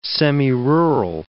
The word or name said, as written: semirural